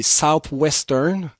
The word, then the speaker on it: Southwestern: Alors, la façon qu'il le prononce, c'est comme les Français, mais si vous avez pas bien compris, pour vous, les Québécois pur laine, c'est Southwestern.